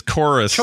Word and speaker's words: Chorus: Yeah, it's supposed to be Chorus. And that's how they say it, but they spell the U or the U in the logo as a V, and it annoys me.